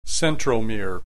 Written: centromere

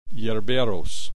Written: yerberos

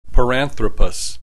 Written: Paranthropus

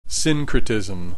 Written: syncretism

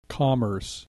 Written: commerce

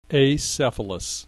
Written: acephalous

